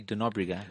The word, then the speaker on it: de Nóbrega: It's Portuguese. It's supposed to be de Nóbrega, but they took the day away.